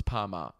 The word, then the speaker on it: Parmigiana: It's spelt Parmigiana, but it's pronounced Parmigiana. So it's Parma.